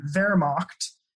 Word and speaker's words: Wehrmacht: So the Wehrmacht, it's spelled with a W, but pronounced like a V.